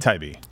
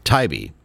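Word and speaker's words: Taibbi: Yeah, they announced via Twitter the thread that Matthew, is it Tiabi? – Tybee. – Tybee.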